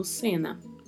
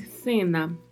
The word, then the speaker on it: cena: E muita atenção à pronúncia desta palavra. Existem duas possibilidades. A primeira é pronunciar com o som de S mesmo, cena. E a segunda é pronunciar com o som que é gerado colocando-se a língua entre os dentes. É semelhante ao som do thank you do inglês. Então falaria-se cena, cena. Então, há as duas possibilidades de pronúncia: cena ou cena.